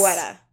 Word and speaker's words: Guetta: It looks like it would be pronounced Guetta, I guess? – Guetta, yeah.